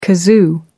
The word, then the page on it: kazoo: American English